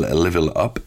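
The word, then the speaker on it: LVL UP: L'album, le groupe s'appelle Level Up, et ça s'écrit L-V-L, espace up, donc il n'y a pas de E dans Level.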